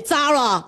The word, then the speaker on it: Zara: 西班牙语 Zara。这个 z 啊 z，你在西班牙语当中是那个那个像我们英语当中 th 那个声音，the the 咬舌头啊，the the 那，所以呢。 Z A 那就是 the the，嗯，那个 R R 那英语当中是 r r r，对不对啊？R 啊，那在那个西班牙语当中啥呢？是 o o o，所以 Zara